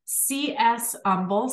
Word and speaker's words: Umble: C.S. Umble, spelled like humble.